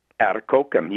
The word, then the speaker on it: Atikokan: Atikokan, yes.